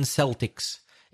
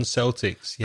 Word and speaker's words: Celtic: So when it's for sport, we pronounce it Celtic usually, but the normal pronunciation of that word is with a K, Celtic.